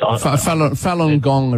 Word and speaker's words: Falun Gong: There's about 60-odd people down there at the moment, holding banners saying stop the persecution of Falun Gong, I think it's pronounced. – Falun Gong it'll be.